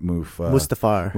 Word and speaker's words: Mustafar: And we were on, I always mispronounce it, Darth Vader's planet, Mustafar.